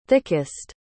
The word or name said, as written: thickest